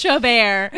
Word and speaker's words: Chabert: It's pronounced Chabert.